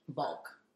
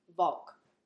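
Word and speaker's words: Walch: Volk. – Volk. Okay. – Because it's a German pronunciation. – Oh, okay. – Or Austrian. – Austrian, right. – So, the W is a V.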